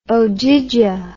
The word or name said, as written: ogygia